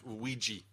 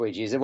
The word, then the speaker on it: Ouija: Ouija, but it is pronounced Ouija. – Yeah, it is pronounced Ouija.